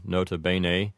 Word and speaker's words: nota bene: I can't really think of any other way to pronounce it other than nota bene. I suppose that an English speaker might shorten the first E in bene and pronounce it like bene. So in that case, the whole thing might sound like nota bene. But besides that one little difference, I think this one's pretty straightforward.